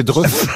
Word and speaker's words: Dreux: Vous le prononcez mal, mais c'est Dreux.